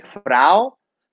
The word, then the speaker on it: Frao: Mis redes sociales bajo mi nombre, Frao. Frao se escribe F-R-A-O.